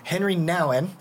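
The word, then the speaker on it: Henri Nouwen: Henry Nouwen.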